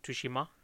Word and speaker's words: Tsushima: The game we're talking about, of course, is Ghost of Tsushima.